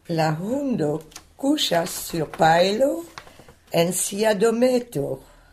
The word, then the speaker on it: pajlo: Alors, paello, c'est de la paille. Très bien. Ça s'écrit P-A-J-L-O. Il n'y a donc que deux syllabes. Paille et puis l'eau. Donc, il faut dire paello.